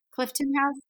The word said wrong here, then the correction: Cliveden: Cliveden. Cliveden. Yep.